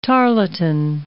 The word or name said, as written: tarlatan